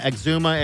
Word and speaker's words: Exuma: They said eczema, right? Exuma? How do you say that? – Yeah, Exuma.